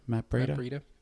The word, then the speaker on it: Matt Breida: Did I say that right, Matt Breida? – Matt Breida. No, yeah. Breida's right. Yep.